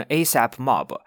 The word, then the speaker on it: ASAP Mob: ASAP 就是 ASAP 啊，这个 ASAP Mob 啊，就读成 ASAP Mob 啊，是一个说唱的团体组织，里面最出名的就是 ASAP Rocky。这里面所有的人，他名字的开头都是 ASAP ASAP。总之，Ferg 他曾经是 ASAP Mob 里面的一员，但是后面呢，啊，好像是跟里面的一些成员发生了一些不愉快，然后就说是他被这个团体踢出去了。